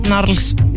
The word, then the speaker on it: Gnarls: þetta dúo Gnarls segir maður ekki Gnarls, skrifa Gnarls en heldur þetta að sé Silent G já,